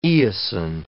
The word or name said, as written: eosine